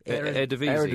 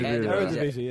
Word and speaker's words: Eredivisie: how do you say the Dutch division? – Eredivisie. – Eredivisie, yeah.